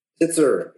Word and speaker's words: Titzer: Titzer.